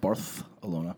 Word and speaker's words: Barcelona: For the record, it's pronounced Barth-alona.